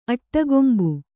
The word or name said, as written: ಅಟ್ಟಗೊಂಬು